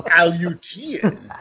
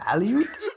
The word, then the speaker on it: Aleutian: I just want to give a shout out for pronouncing Aleutian correctly, because my dumb ass was like, what? – Aleutian. – Aleutian.